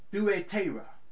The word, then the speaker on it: Thyatira: It is pronounced in the Greek thuetera, and it means the odor of affliction.